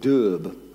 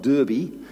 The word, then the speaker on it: Derbe: But they are in a place called Derbe. D-E-R-B-E, and I have it on good authority that it's pronounced Derb, not Derby, because there's no indication he ever made it to the Midlands.